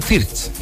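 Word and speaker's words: Wirtz: Leroy Sané, Jamel Musiala, Florian Wirt, no, es Florian Fiertz. Florian Fiertz, así se pronuncia.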